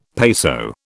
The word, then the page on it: peso: British English